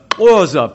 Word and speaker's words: Xhosa: If we were in amongst the Xhosa people down in the southern part of Africa, and by the way, Xhosa is spelled X-H-O-S-A, and X is a...